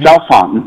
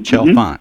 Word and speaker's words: Chalfant: Chalfant. Mm-hmm. – Chalfant.